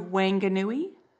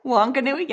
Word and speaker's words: Whanganui: We went there, and and then all the way back down through Whanganui. – Whanganui? – Yeah, there you go.